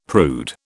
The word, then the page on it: prude: British English